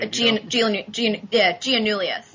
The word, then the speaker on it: Giannoulias: Giannoulias, Alexei Giannoulias.